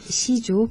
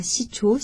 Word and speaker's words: Sijo: Sauf il faut bien prononcer Sijo, pas Sijo.